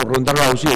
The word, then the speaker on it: Ronda Rousey: Ronda Rousey, sí.